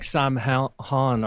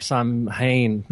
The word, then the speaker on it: Samhain: It looks like Samhain or Samhain, like most people in America pronounce it, because they don't have the pronunciation rule.